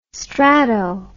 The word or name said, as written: strato